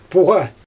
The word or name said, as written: puGa